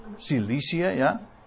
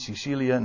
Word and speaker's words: Cilicië: En Tarsus is dus, de, dat was de hoofdstad daar van, het, van Cilicië, zeg ik het goed? Cilicië, ja niet Sicilië, nee, Cilicië in Zuid-Turkije.